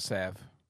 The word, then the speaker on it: salve: It's called salve. – Salve. My bad.